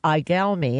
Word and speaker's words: Igalmi: Igalmi, and let me spell it for pe- for listeners.